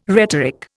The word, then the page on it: rhetoric: Indian